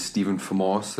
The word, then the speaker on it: Stephan Vermaas: my name is Stephan Vermaas.